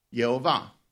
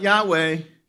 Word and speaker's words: Yehovah: How do you pronounce God's name? Yehovah. It's not Yahweh. It's not Yahweh. This is the way Israelis pronounce it, and they won't pronounce it aloud.